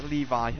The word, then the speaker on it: Levy: Anyway, it's pronounced like Levi. Alright, Barrington Levi, just like the jeans.